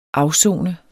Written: AFSONET

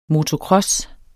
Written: motocross

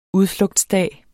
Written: udflugtsdag

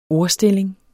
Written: ordstilling

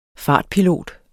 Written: fartpilot

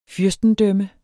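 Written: FYRSTEDØMME

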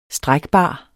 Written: strækbar

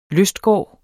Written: lystgård

